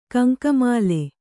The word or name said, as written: ಗಂಗಾಮಾಳ